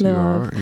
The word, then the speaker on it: Lvov: it says that when you were born in Lvov, am I saying that correctly? I hope. – Yes, Lvov, you are.